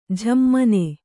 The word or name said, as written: ಝಮ್ಮನೆ